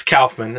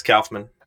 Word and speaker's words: Kaufman: It's Kaufman. It's Kaufman.